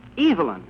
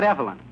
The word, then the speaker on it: Evelyn: And in England, a man's name is pronounced Evelyn, not Evelyn.